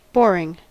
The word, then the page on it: boring: American English